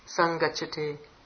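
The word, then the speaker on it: saṅgacchati: Sang gachati. So it can be written either way. It should be pronounced sang gachati.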